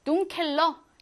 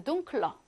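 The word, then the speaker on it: dunkler: 둥켈 어두운이란 뜻인데 원래 여기 애가 있어서 둥켈러 해야 하지만 발음이 어려워. 그래서 둥클러 이렇게 쉽게 발음을 하기 위해 애를 탈락시켜 버렸어요.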